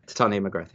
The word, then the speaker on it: Titania McGrath: Titania McGrath, yeah.